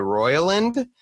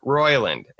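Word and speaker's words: Roiland: But apparently he goes by Royland, and I can't read good. So I miss the I completely, mostly. So don't beat me up, okay? Don't be slandering me in all the emails about my pronunciations, okay? I got it. I missed a letter.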